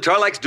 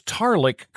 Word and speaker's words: Tarlek: Okay, now, I don't know if this is intentional, but Peter Marshall never pronounces Tarlick correctly. – Right, Tarlack. – Tarlack. Uh-huh. It's slightly off every time he says it.